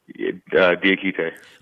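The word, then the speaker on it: Diakité: And, you know, that kid, is it Diakite? Is that what, how you would say it, Diakite? Is that how do you pronounce his name? – Diakite.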